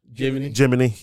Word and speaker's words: Jiminy: Gemini, I think, but it's pronounced... – Jiminy, yeah.